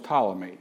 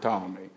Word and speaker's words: Ptolemy: Then the fourth general was Ptolemy. I'm sure you've heard of Ptolemy. The Ptolemaic Empire, that's spelled with a P.